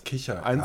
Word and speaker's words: Kichererbsen: Hat zwei RR. Kichererbse. – Ja. – Aber beide spricht man völlig unterschiedlich aus. – Kichererbsen. – Eins ist ein R und das andere ist ein R.